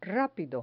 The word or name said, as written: rapido